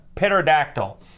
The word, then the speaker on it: pterodactyl: The P is silent. You never learned that in school, pterodactyl?